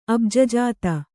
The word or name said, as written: ಅಬ್ಜಜಾತ